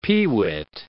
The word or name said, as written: peewit